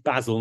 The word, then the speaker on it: Basil: I'm excited to hear more about it from Basil today. Thank you for joining me. – Hi, Adam. And as you said, it's Basil, not Basil. I'm British and we pronounce Basil as Basil.